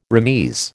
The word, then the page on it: remise: American English